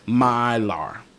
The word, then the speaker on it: Meijer: Mylar. M-E-I-J-E-R.